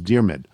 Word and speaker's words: Diarmuid: And I got a note from a first name that I was totally unfamiliar with, D-I-A-R-M-U-I-D. It's an Irish name pronounced Dermid, and it's Dermid O'Connor.